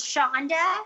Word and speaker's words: Chonda: It's pronounced like Charlotte. So the CH is like Charlotte is Chonda.